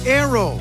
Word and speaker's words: Naoero: This program is previously called the country Nauru, N-A-U-R-U. The 11,000 residents have altered Nauru to be now Aero, N-A-O-E-R-O. They're going back to the pronunciation in their native language and losing a pronunciation used by outsiders.